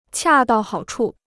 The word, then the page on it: 恰到好处: qià dào hǎo chù